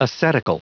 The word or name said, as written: ascetical